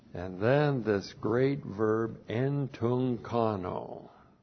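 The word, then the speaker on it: entunchano: Looks like that in the Greek, that's E-N-T-U. Now we got two gutturals. When you get them together, the first one you transliterate like an N, and then C-H-A-N, long O, entunkano.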